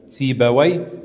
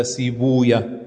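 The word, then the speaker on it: Sibawayh: Wie gesagt, zu den Lehrern gehört Ahmed ibn Hanbal, dazu natürlich auch Yahya ibn Ma'in, Uthman ibn Abi Shayba, Ishaq ibn Rahuya oder Rahaway, kann man, also diese persischen Begriffe werden unterschiedlich ausgesprochen, also können unterschiedlich ausgesprochen werden. Entweder du sagst Rahaway oder Rahuya, Sibaway oder Sibuya, ja.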